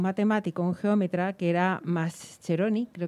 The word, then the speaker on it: Mascheroni: No lo conozco, pero sé que los italianos la C y la H la pronuncian como si fuese una Q. – Mascheroni.